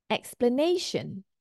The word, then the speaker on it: explanation: Explanation. So it looks like this, or it sounds like this. Explanation. Nation. The stress falls on the third syllable here. This is our first stress or primary stress. And the secondary stress is marked with this line down here for the first syllable.